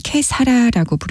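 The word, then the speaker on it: Che sarà: 여기서 이용복은 이탈리아 발음인 캐사라라고 부르고 호세 펠리치아노는 스페인어인 캐세라라고 부릅니다.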